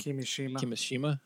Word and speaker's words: Kimishima: Tatsumi Kimishima.